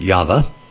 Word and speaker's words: Yahweh: and it is YHVH, the Tetragrammaton, or pronounced Yahweh.